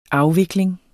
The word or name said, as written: afvikling